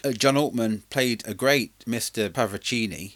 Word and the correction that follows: Paravicini: No, it's not. I've done said that wrong. – Paravicini. – Paravicini.